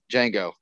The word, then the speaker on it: Django: Django. How do you spell it? D-J-A-N-G-O. The D is silent.